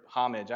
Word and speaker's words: homage: By the way, do you say homage or homage? I say homage, so if that weirds you out, I don't pronounce the H, it's a homage.